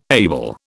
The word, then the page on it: able: British English